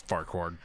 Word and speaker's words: Farquaad: I thought Scrappy might have just been pronouncing it wrong, but no, it's Farquaad.